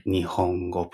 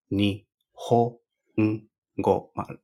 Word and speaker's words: nihongo: Nihongo, pronunciamos nihongo, pero ni empezando como bajo y después ho subimos el tono. Ni ho un go. Eh, después un go es mismo tono que ho.